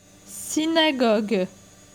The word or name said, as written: Synagogue